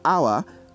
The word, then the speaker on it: hour: Please take note that words like hour, honor, air are pronounced without having to use the H as a sound. This means they will be pronounced as O-U-R, O-N-O-U-R, and E-I-R respectively.